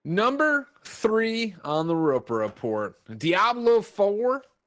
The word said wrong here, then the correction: Diablo: Why did I say it like that? I have no idea. Diablo 4.